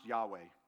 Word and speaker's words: Yahweh: This is, it's pronounced Yahweh. In the Hebrew, they didn't use vowels.